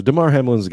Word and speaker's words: Damar Hamlin: Damar Hamlin.